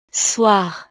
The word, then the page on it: soir: frances